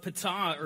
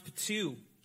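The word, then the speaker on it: Ptuj: and his name is Victorinus of Ptah, or it's pronounced Ptu, and he was an early Christian theologian, obviously, from the third century, a writer.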